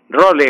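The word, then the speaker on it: rôle: Role en francés, escrito así, pues se pronuncia role sin sonar la E final.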